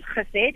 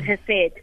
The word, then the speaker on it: Chesed: I traditionally pronounce Chesed in Hebrew, but most of us just pronounce it Hesed.